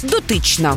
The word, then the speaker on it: дотична: Сьогодні ми поговоримо про слово «дотичні» або «дотична». Наголос на другому складі «дотична» – значення таке.